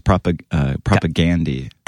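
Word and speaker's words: Propagandhi: But except it's great that in Canada, you know, we say everything with a hard A, but it's Propaganda. – Propaganda? – Yeah. – Oh my God, I've been saying this wrong for 20 years. – No, you're saying it correctly, but in Canada, you know, we have a hard A on everything.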